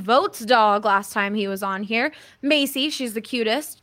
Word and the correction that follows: Maisie: It's Maisie. It's like Daisy.